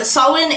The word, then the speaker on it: Samhain: Samhain is commonly mispronounced as Samhain because that is how it's spelled, and language does that.